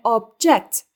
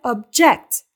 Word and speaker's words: object: Aynı şekilde obje anlamına gelen object kelimesinde vurgu ilk hecededir. Yani object şeklinde telaffuz edilir. Ancak itiraz etmek anlamına gelen object fiilinde vurgu ikinci hecededir ve object şeklinde telaffuz edilir.